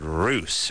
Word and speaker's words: Russ: David Roos from Seattle. So he'll be coming in here as well. – How does he spell his last name? – Well, he spells it R-U-S-S, but it's pronounced Roos.